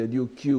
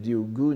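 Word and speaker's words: Ryūgū: Enfin, on peut prononcer Ryukyu ou Ryugu euh, ici c'est Ryugu, n'est-ce pas, le, palais des dragons, la lecture bouddhique.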